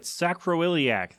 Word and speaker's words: sacroiliac: All right, sacroiliac.